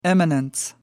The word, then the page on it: eminence: American English